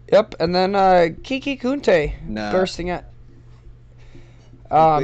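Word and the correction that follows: Coutee: Saying his name right. – Kuti. Kuti. Kiki Kuti. I said it right.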